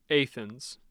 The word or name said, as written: athens